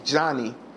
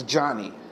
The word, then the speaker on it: Johnnie: My name is Johnny Simpson Jr. Not John, not Jonathan, not John John, not John Boy. It's Johnny. And it is spelled J-O-H-N-N-I-E.